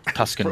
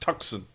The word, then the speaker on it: Tucson: Tuscan Roadrunners. – It's pronounced Tucson.